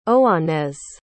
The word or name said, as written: Oanez